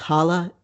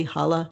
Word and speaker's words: Xala: I think it's pronounced Hala, X-A-L-A, Hala, and Guerawar.